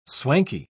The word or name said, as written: swanky